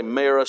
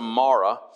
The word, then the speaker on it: Mara: Call me not Naomi, call me, you can either long or short A, some say Mara, some Mara.